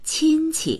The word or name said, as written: qin1qi